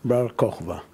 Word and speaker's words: Bar Kokhba: Bar Kokhba.